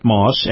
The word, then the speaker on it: Maus: Now, this portion of the program brought to you by our friends at Moss Acura. Moss Acura, of course, in North Tampa, located at North Florida Avenue, right next door to the Moss Nissan dealership. Now, Moss is spelled M-A-U-S.